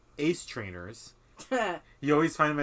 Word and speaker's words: Ass: so Ass in German is Ace. But it's spelled A-S-S.